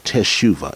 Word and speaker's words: teshuvah: teshuva. It's spelled T-E-S-H-U-V-A-H, teshuva.